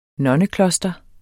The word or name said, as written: nonnekloster